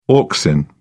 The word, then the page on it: auxin: British English